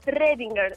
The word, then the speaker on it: Schrödinger: Es como una O en plan Schrödinger, ¿sabes? – Schrödinger.